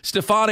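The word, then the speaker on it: Stephone: S-T-E-P-H-O-N-E. I'm guessing that it's pronounced Stephon, though. Stephon Anthony, linebacker of Clemson. Any idea, Lonzo? – Yeah, it's Stephon Anthony.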